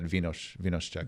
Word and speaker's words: Vino z Czech: The Czechs would pronounce that vee-nos Czech.